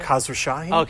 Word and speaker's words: Khosrowshahi: Yeah, Uber CEO, actually, the new Uber CEO, Dara Khosrowshahi, sent a memo. – Wait, how do you say that again? – Khosrowshahi? – Okay, just making sure.